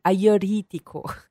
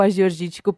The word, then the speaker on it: Agiorgitiko: E é até legal falar o nome dessa uva, porque eu aprendi com um grego e ele pronuncia diferente. Então, eu até agora espero que eu tenha aprendido, mas eles falam agiorítico.